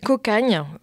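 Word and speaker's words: Cocanha: Ouais, j'ai écouté Cocada, c'est vraiment pas mal du tout. Hein. – Ouais, alors on dit Cocagne. – Cocagne, d'accord. – Ouais, ça s'écrit Cocagna, mais ça se dit Cocagne.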